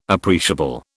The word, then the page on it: appreciable: British English